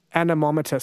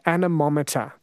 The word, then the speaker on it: anemometer: Harry has just said it's pronounced anemometer. Sorry about that. Anemometer.